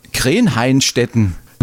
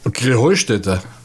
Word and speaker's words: Krähenheustätte: Ja, ihr saget immer Krenheinstetten. Und dabei heißt es doch Krähenheustätte, oder wie? – Krähenheustätte. – Ja, genau, und das die Betonung auf dem Heu.